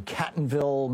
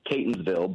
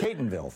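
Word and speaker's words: Catonsville: It's Catonville, but... – Catonville, thank you. – Everybody mispronounces it, so I take no offense.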